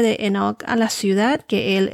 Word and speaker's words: Enoch: En inglés se pronuncia Enoch. En algunas traducciones de las Biblias lo deletrean como con H, H-E-N-O-C, en vez de E-N-O-C.